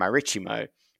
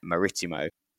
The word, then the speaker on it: Marítimo: And I keep switching between Marítimo and Marítimo because Marítimo is sort of the Brazilian pronunciation, which is the very limited knowledge of Portuguese I have is Brazilian Portuguese. But I kept getting corrected yesterday to say Marítimo.